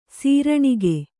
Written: ಸೀರಣಿಗೆ

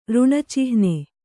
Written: ಋಣಚಿಹ್ನೆ

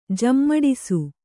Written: ಜಮ್ಮಡಿಸು

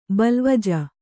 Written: ಬಲ್ವಜ

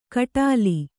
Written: ಕಟ್ಟೋಲೆ

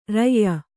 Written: ರೂಯಿ

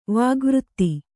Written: ವಾಗ್ವಾದ

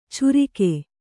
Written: ಚೂರಿಕೆ

